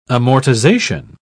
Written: amortization

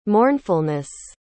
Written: mournfulness

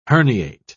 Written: herniate

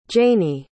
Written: Janee

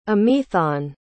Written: amaethon

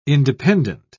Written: independent

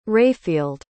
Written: Rayfield

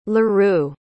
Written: leroux

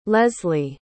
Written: lesli

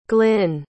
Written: glyn

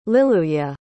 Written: liluye

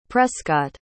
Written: prescott

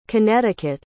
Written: connecticut

